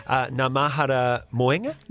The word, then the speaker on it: Ngā Mahara Moenga: which is Ngā Mahara Moenga. – Yep. – I pronounced that correctly? – That's right, yep, that's yep.